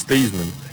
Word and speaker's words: Theismann: You pronounce his name is Theismann, but they wanted to do a Heisman campaign for him at Notre Dame, and he became Theismann.